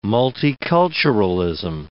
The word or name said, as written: multiculturalism